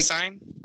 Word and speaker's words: Syne: It's like Syne. Like Lane Staley, I think is how it's pronounced.